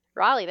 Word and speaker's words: Raleigh: Raleigh, that's correct.